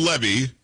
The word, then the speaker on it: Levy: So Dan Levy, because it was Eugene Levy's son, that's how they pronounce it.